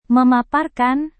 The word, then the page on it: memaparkan: Indonesian